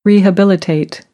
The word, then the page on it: rehabilitate: American English; ə